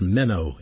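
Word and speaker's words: meno (μένω): The word abide is a Greek word pronounced meno, M-E-N-O, meno, and it means not to depart or to remain as one.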